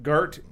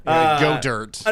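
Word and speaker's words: Goedert: It's G-O-E-D-E-R-T. – Godard? – Godard? Gert? Yeah. I'm sure it's not Gert. – Gert? – Godert? Yeah. – Godert. – Unfortunately, his first name is Dallas. Dallas Godert.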